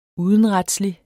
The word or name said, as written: udenretslig